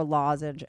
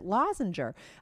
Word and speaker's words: lozenge: So I went onto line and I Googled lozenger or lozenge. And I found this guy who wrote this lozenger. What the fuck? Even though it's always written lozenge, it seems like half the people pronounce it lozenger.